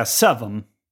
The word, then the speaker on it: seven: på lokal slang så säger man inte seven, utan man säger seven. Med U. Seven.